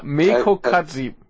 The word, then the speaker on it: Meiko Kaji: Er hat ja auch eins über äh, Maiko Kaiji gemacht. – Na, wie heißt sie? Wie heißt sie? – Ich habe sie wieder falsch ausgesprochen, war? Ja, Meiko Kaji. – Kaji, Meiko Kaji, ja.